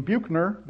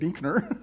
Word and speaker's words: Buechner: Some people pronounce his name Buechner, but they are wrong. I pronounce it Buechner.